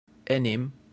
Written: enim

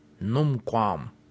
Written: numquam

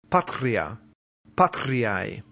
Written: patria

